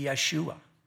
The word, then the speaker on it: Yeshua: But if we were pronouncing this in like the Hebrews would, it would be Yeshua.